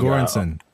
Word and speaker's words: Göransson: Like we got Wheezy Ludwig Gorens. I, I, don't know how to pronounce that. – Ludwig Göransson.